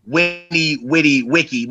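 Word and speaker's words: veni, vidi, vici: right after Winnie Witty Wiki, which is the correct pronunciation, but ain't nobody pronouncing it like that. We're Americans. – Facts. – Right after Vinny Vitty Bitchy.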